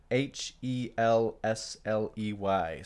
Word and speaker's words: Helsley: So H E L S L E Y.